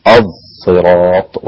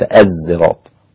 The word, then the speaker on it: صراط: بالصاد المشمه بالزاي وليست زايا، الصراط وليس الزراط، الصراط هكذا، تمام.